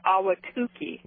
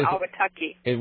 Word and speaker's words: Ahwatukee: I just wanted to call in with the correct pronunciation for Ahwatukee, not Ahwatukee. – What did I say? – You said Ahwatukee. – And it's Tukey. – Tukey. – Okay, Tukey. Got it.